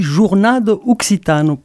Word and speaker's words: Jornada Occitana: Alors, j'ai dit Jornada Occitana, mais ce n'est pas exactement comme ça qu'on le prononce. – Et non, ça, c'est tel que les Français le lisent. Nous, on dit Jornada Occitana, parce qu'il y a des règles pour lire l'occitan, bien sûr.